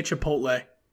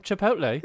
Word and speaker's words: Chipotle: Hey, at least you actually say it right, because, you know, like, lots of people in my neck of the woods, they always want to say Chipotle, and I'm like, oh, that's the worst.